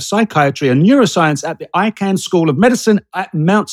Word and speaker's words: Icahn: ICAHN.